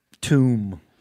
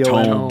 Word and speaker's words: tome: A big tomb. Is that how you say it? – No, tome. Tome. – Tome. Tombs where you put dead folks.